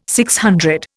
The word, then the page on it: DC: Indian